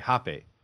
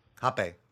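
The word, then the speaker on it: rapé: Okay, hape. – Hape. – So we want to have a hape. – Yeah, yeah. Hape. But it's spelled. Should I spell it? It's spelled R-A-P-E.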